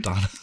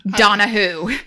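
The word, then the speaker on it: Donowho: No, it's W-H-O at the end of his name. Donahue. Like who? It's D-O-N-O-W-H-O. Donahue.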